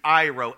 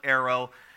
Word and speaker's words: airo: And the Greek root word for takes away is Airo, A-I-R-O. Some people call it arrow. I have a Greek pronunciation device on my computer, Airo. So if you call it arrow, repent and be baptized. Airo is the correct pronunciation.